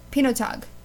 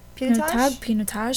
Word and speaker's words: Pinotage: But the wine pairing is Pinotage. Am I pronouncing that right? – Pinotage? Something like that.